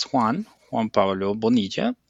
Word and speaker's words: Juan Pablo Bonilla: As you mentioned, my name is Juan Pablo Bonilla. Sometimes it's a hard name to pronounce, but it's a Spanish name, of course.